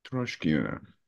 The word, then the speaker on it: Troshkina: Troshkina. First, uh, for, for, for first uh, O. – Okay, Troshkina.